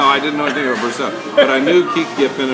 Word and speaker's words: Brosseau: Brosson? – Brosseau.